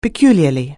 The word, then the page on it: peculiarly: anglais